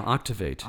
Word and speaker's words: Octavate: You have also have an online training course called Octavate. Am I saying that right? – Yeah, Octavate.